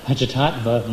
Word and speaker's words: Panchatattva: We chant the Panchatantra mantra. Wow, did I say that right? Panchatattva mantra. That's a hard one.